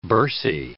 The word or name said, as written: bursae